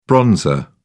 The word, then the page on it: bronzer: British English